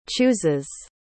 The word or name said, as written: chooses